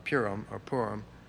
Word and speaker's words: Purim: And then Esther is read at the Feast of Purim, is the way it's pronounced.